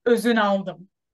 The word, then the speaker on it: Ozunaldim: But my last name is even tricky for Turkish people. It's Ozunaldim.